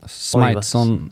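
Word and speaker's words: Smythson: Ja, Smithson. – Smithson, tror jag. – Svårt det blev. – Ja, jag vet. – S-M-Y-T-H. – Precis.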